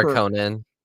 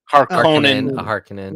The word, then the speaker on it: Harkonnen: Harkin Trooper. – Harkonnen. – Harkonnen.